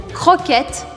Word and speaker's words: croquette: Croquette, métro. Croquette, métro. Croquette, você vai fazer aqui no meio da garganta o som do R.